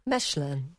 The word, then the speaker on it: Mechelen: Right, OK, this is a shout-out then to anybody living anywhere near the place we've decided is pronounced... – Mechelen.